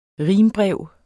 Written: rimbrev

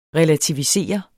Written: relativisere

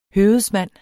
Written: høvedsmand

